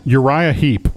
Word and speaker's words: Uriah Heep: Uriah Heep.